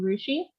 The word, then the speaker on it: Rusche: My name is Jordan Rusche.